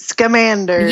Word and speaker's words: Scamander: Because whenever I read it, I like don't know how to pronounce the last name, but it's Salamander. – Newt Scamander. – Yeah, that, yeah. Scamander, yeah, yeah. I know, it's like Scamander.